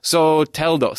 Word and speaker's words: Soteldo: And then you bring in Jefferson I've been saying his name wrong. I just found this out over the weekend. Soteldo. So Jefferson Soteldo.